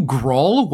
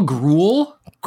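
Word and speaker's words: Gruul: Are are you Grawl? What guild are you? Did you say Grawl? Groll, Gruel. I think I think it's pronounced Gruel. Gruel makes sense.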